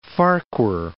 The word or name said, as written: Farquhar Islands